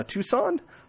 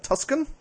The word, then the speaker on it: Tucson: Yes, it was a presentation by Charles Gerba of the University of Arizona, Tucson. I am very proud I didn't pronounce that Tuscan.